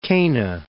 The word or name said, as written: Cana